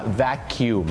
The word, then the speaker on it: vacuum: vacuum ไม่ได้ออกเสียงว่า vacuum เหมือนที่พี่น้องชาวไทยบางท่านอาจจะออกเสียงเพราะเข้าใจผิดนะครับเขาจะต้องออกเสียงว่า vacuum นั่นเองครับคล้ายๆแมงมุมขยุ่มหลังคา vacuum หรืออย่างน้อยก็ vacuum สระอูน่าจะดีกว่า